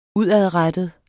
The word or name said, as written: UDADRETTEDE